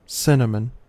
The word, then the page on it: cinnamon: American English